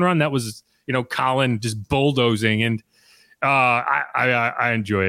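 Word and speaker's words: Khalen: You said Colin. Yeah. It's not Kalen. – Well, because right, a lot of people call him Kalen. The funny thing is the Colin Saunders Twitter handle is Colin, not Kalen.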